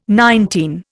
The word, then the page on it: nineteen: Indian